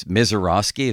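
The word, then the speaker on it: Misiorowski: Let's do our first item on that, is that pitching matchup to begin the second half, because this Mizorowski, is that how you say his name? – Yes.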